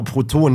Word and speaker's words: Proton: Das hat nichts mit elektronisch positiv geladenen Teilchen zu tun, sondern eher Proton. – Ja, genau, Proton. Die Aussprache ist eigentlich auch schon wichtig.